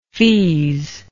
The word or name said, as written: feaze